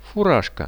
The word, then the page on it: furazhka: Russian